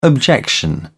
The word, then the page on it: objection: British English